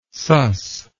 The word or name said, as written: suss